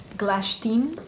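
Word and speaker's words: Glashtin: But as I've heard in some YouTube videos, it's actually pronounced Glashtim.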